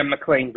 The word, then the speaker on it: McLean: McLean, Virginia. Did I pronounce that right? – Yeah, McLean, Virginia.